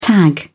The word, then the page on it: tag: British English